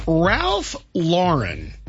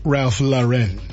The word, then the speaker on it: Ralph Lauren: And Ralph Lauren, that's how I pronounce his name, not Ralph Lauren.